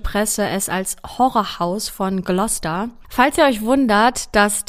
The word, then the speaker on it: Gloucester: Ähm, genau, also sie heißt Gloucester, wird aber eher Gloucester geschrieben, also nicht wundern an dieser Stelle.